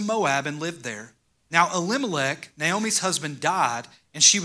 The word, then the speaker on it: Elimelech: The man's name was Elimelech, and I said that right.